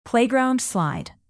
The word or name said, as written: playground slide